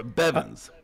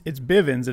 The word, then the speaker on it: Bivens: Is it Bivens or Bevins? – It's Bivens,